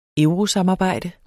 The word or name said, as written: eurosamarbejde